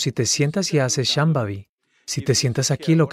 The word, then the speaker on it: Shambhavi: ¿Puedes pronunciar Shambhavi? Shambhavi. Sí, estupendo.